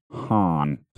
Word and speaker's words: Haan: But I did find the cool band I didn't know of called, I'm assuming it's pronounced Han. I dug that stuff. – The Aqualam guys. – Aqualam. – Hey, Aqualam. – Yeah. H-A-A-N. Han.